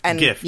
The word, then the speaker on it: GIF: GIF. It's a hard G. It stands for graphic.